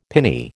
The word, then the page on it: pinny: American English